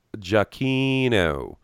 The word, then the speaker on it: Giacchino: I said his name wrong. It's Giacchino.